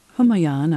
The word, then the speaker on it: Humayun: Babur's son and Humayun, I hope I'm pronouncing that correctly. – You pronounce it absolutely right.